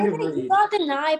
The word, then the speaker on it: Kenai: How do you say it? – It's Kenai. – Kenai. I said Kenai. – I know, that's still wrong. – That's still wrong. Okay. Okay. No partial credit.